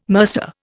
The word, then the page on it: mercer: Indian